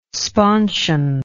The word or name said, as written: sponsion